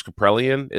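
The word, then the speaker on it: Kaprielian: The biggest discrepancy right now in terms of performance is the Friday night matchup, which is Senga versus James Caprellian.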